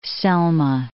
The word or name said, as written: Selma